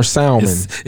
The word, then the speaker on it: salmon: Which are is salmon or salmon? – It's salmon, bro. – Salmon. – I say salmon. – The L is silent. – Salmon or salmon? – Yo, the L is silent, bro.